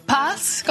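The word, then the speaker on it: PATS: Tu es également enseignant et directeur du programme francophone du doctorat au PASS. Comment on le prononce? – PASS. Pas, ça veut dire le chemin en anglais, mm-hmm.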